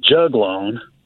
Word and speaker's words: juglone: The reason I say that, I've read about a content in those leaves called juglone. I believe I'm pronouncing that right. – Yes, sir.